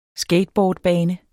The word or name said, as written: skateboardbane